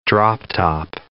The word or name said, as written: drop-top